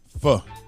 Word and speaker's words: pho: The spelling of the word is P-H-O. How do you say it? – Fuh. – That's it for real? – Fuh. – Fuh or fo? – No, fuh.